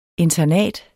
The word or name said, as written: internat